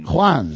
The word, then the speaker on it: Juan: Did you say Juan or Juan? – Juan. That's how you pronounce it.